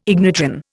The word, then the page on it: ignitron: Indian